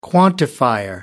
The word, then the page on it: quantifier: American English